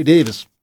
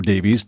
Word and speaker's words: Davies: And Ray Davis, or Davies, depending on how he wants to pronounce it for the day, will be 82 tomorrow.